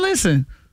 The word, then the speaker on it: listen: Listen is another one where the English words has a silent letter. – Silent and listen. – T. Listen. You ain't no T in listen.